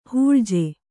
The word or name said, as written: ಹೂಳಜೆ